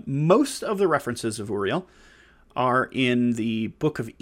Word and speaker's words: Uriel: Uriel is spelled A U R I E L or U R I E L. Uh, pronounced Uriel, and it means shining light of God. stems from the words Ur uh, and El.